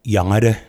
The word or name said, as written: Yangade